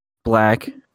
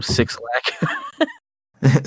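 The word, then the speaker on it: 6lack: Black. I like his music a lot. That's how you say it, right? – Yeah. – Okay. I always called when I was younger. I always called him Six Lack.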